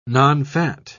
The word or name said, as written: nonfat